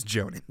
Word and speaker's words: Jhonen: It's pronounced Yarn. – Oh, is it? – No, I had to learn this week that it was Jonan.